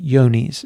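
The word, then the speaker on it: Johne's: So this time they tested for BVD and for a disease called Yoni's, is the way it's pronounced.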